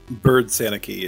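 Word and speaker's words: Byrd-Sanicki: Bird Sanicky is how you say Megan's last name.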